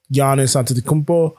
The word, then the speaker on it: Antetokounmpo: Yeah, so then in recent days, LeBron has come out against the All-Star game as well as Giannis Antetokounmpo.